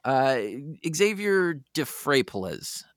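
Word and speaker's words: Xavier Dphrepaulezz: Xavier DeFrapolis, I think is how he pronounces his last name.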